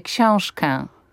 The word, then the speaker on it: książkę: Po pierwsze tę książkę, żeby mu podać, a nie tę książkę, ale że powie książkę, książkę, że to ę zabrzmi nie jako książkę, bo to jest błąd. Tak. To jest hiperpoprawna wymowa, książkę, rękę, ale że to ę wybrzmi, rękę.